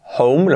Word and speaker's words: home: Cuidado la pronunciación, home, lo mismo la H, home, como en house, aspirada así, house, que no sea house, house. Lo mismo aquí, home. Con ese sonido, house, home.